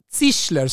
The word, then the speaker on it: Zischler: Hans Sichler. Sich Zischler, svårt att uttala. Z och SCH. E Zischler.